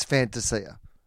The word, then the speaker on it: Fantasia: And he said, well, technically, it's Fantasia.